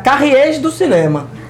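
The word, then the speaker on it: Cahiers du Cinéma: Eu, o certo é, é pronunciar carriês do cinema.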